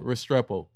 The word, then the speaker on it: Restrepo: Restrepo.